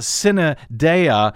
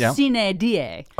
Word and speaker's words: sine die: Sine die. Actually, the way that's a Latin phrase, I think it's actually pronounced sine dea. But according to my Latin teacher, it was sine die.